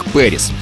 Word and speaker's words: PVRIS: Тобто назва команди пишеться як PVRIS, Вріс, але читається як Періс.